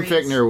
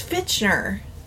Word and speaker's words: Fichtner: It's Fichtner. Is it Fichtner? Fichtner? That's how it's spelled. I think it's pronounced Fichtner.